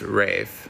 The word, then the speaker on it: Ralph: So there's Joseph and there's Rafe. – Yes. – And it's spelled Ralph, but they call him Rafe.